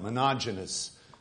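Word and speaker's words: Monogenous (μονογενής): It's the Greek word monogenous, and it's spelled like monogenes, and it's one word. Monogenous is the way that it's pronounced, and it means only begotten, only coming forth, the only one that's coming forth, right?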